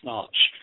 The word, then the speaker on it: Schnarch: I learned it from Dr. David Snarch. It's spelled S-C-H-N-A-R-C-H.